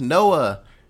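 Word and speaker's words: Noa: So, just like I said before, we meet up with Noah, and not N-O-A-H, N-O-A, like rhymes with boa.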